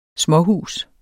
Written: småhus

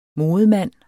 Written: modemand

